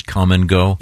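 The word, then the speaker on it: Kum & Go: Are you familiar, Chick, with the convenience store chain known as Come and Go? – I am. And K-U-M. – It's spelled K-U-M, fortunately.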